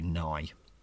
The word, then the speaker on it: Nagy: Benik Afobe and Adam Nye, I've been told is Nagy. how you pronounce his name. I much prefer saying Nagy, so I might just stick to Nagy. So there's three different pronunciations here. – You say Nagy. Yeah. I prefer to say Nagy, but I've been told it's actually Nye.